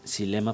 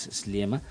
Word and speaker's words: Sliema: Una de las más importantes es Sliema, es eh, medio difícil de pronunciar, algunos le dicen Silema, pero no es Sliema.